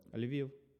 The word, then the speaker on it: Lviv: Lviv. L-V-I-V. Lviv. – L-V-I-V. Okay. – Lviv. – L-V-I-V. Okay. – Like Tel Aviv, but Lviv. Just Lviv.